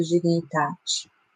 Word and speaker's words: dignitate: E eu fiquei feliz porque a pronúncia se parece mais o italiano. Então não é tão difícil para mim, né, que é mais perto. Então, dignitate. Mas a gente pronuncia como pode, não tem problema.